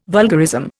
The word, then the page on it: vulgarism: Indian